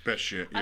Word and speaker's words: Bechet: I guess that's how you say it. Bechet, probably. – Bechet, yeah.